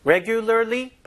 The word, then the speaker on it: regularly: Regularly 발음이 좀 어려워서 그렇죠? Regularly 이거를 생각 대부분 다 하셨을 것 같아. 아이고 발음 어렵다. 왜 어려워요? 한국 사람한테 제일 힘든 게 R 발음, L 발음이 이게 도대체 몇 개예요? 하나, 둘, 셋, 넷. 아이고 세상에서 제일 우리한테 어려운 발음이에요.